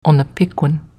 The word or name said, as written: Onapequin